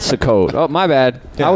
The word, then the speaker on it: Sukkot: Selkat.